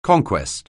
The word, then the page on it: conquest: anglais